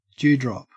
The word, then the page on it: dewdrop: Australian English